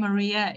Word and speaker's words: Mariya: Just a small thing, I pronounce my name Mariya.